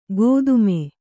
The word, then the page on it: ಗೋದುಮೆ: gōdume